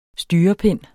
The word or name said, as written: STYREPINDENE